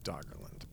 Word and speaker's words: Doggerland: It's actually pronounced Doggerland, but that's fine. – There's no raw? – No, there's no raw.